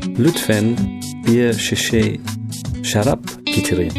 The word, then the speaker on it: getirin: Lütfen bir su getirin.